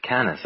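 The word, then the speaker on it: Canice: They actually pronounce it Canis over here.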